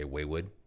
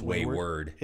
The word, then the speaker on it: Wayward: If you're from New York or if you're a toddler, you would probably say Waywood. – Uh-oh. – But it's Wayward. – It's Wayward. – My bad. Wayward. W-A-R-D.